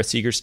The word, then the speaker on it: Seager: with Sara Seager's team. Am I uh, pronouncing her name correctly? – Yes, that's correct.